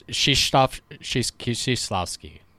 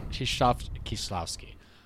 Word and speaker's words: Krzysztof Kieślowski: Well, I did listen to the pronunciation. It's Krzysztof Kieślowski, something like Krzysztof. – I was Kieślowski. – Way off. – So, I was way off too. – Krzysztof Kieślowski. I would say he probably should have won.